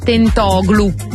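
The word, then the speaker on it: Tentoglou: Stiamo parlando di Tentoglu.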